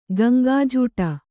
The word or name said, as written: ಗಂಗಾಜೂಟ